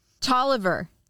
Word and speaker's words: Taliaferro: I want to Google it and see what if we there's a pronouncer. Tolliver. Whoa. Tolliver County.